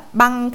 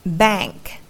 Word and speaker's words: bank: Chứ đừng đọc nó là băng hay là language nha các bạn. Không phải đâu, nó là hơi âm AA một chút. Bank, language.